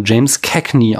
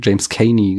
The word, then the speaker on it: James Cagney: Und die haben ihn immer James Cagney ausgesprochen. Also ich glaube, ich habe beim letzten Mal James Caney gesagt. Mhm. Auf jeden Fall hat James Cagney nicht Frau ohne, in Frau ohne Gewissen mitgespielt.